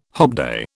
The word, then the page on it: hobday: British English